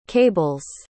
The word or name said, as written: cables